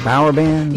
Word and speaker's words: Bauer Band: I say that right, Bauer Band? – Yeah, that's it. You nailed it, like Eddie Bauer and Rubber Band.